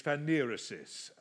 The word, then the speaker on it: phanerosis: The Greek word that's used there is the Greek word phanerosis. I was corrected recently by a Greek lady, and she tells me that the actual pronunciation is phanerosis, okay? So whether it's phanerosis or phanerosis, you've all experienced this.